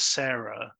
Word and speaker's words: Cera: So, I mean, the thing is, watching it and knowing how the name Sarah was spelt and the way it was being pronounced as Sarah in the film, it was obvious to me that she was a robot.